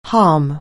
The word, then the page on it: harm: anglais